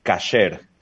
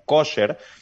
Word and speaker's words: kasher: Kosher, fíjate que la gente lo asume como un tema relacionado con eh, la alimentación, nada más, pero la palabra en hebreo es kasher, con A. Eh, lo que pasa es que, como ustedes sabrán, hay dos formas de pronunciar el hebreo. La forma sefaradí, que es de los judíos de España, eh, y también la forma de los judíos europeos, que la A la pronuncian O. Entonces se popularizó la fórmula ashkenazi y por eso todo el mundo lo conoce como kosher, pero realmente lo que significa la palabra es cualquier cosa que esté lista, preparada, apta, se considera que es kosher.